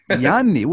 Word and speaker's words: Ianni: Ianni.